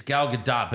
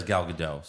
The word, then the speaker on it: Gadot: I think apparently it's Gal Gadot, but everyone says Gal Gadot. So just say Gal Gadot.